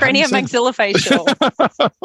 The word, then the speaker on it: craniomaxillofacial: Craniomaxillofacial.